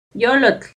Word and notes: yollotl: Náhuatl

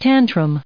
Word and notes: tantrum: noun

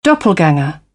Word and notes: doppelgänger: British English

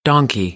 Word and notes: donkey: American English; ɑː - ɒ

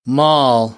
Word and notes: mall: American English; ɑː

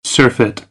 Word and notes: surfeit: American English; ɝː

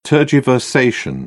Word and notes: tergiversation: British English

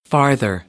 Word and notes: farther: American English; ɑːr - ɑː

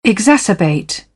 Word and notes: exacerbate: British English

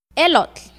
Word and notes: elotl: Náhuatl

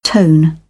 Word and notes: tone: British English

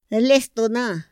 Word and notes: lestona: Otomí

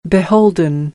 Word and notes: beholden: American English; oʊ

